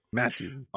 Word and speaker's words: Mathieu: Mathieu.